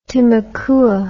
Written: timucua

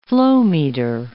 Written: flowmeter

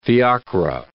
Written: fiacre

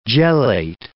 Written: gelate

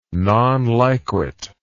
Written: non liquet

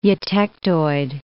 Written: eutectoid

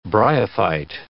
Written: bryophyte

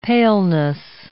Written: paleness

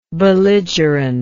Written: belligerently

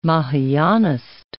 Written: Mahayanist

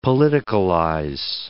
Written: politicalize

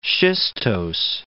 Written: schistose

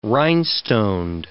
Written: rhinestoned

